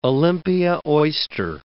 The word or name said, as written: Olympia oyster